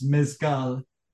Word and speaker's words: mezcal: Well, in Spanish, it's Mezcal.